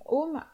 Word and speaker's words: Om: Le U au centre, il est plutôt prononcé comme un O.